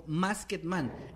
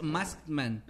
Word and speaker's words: Maskman: Dice, bueno, los escucho siempre y soy profe de inglés y no puedo evitar Ay, evitar querer corregir la pronunciación cuando dicen su amigo Masketman. Es Maskman. Sin la E y con T.